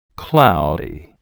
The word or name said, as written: cloudy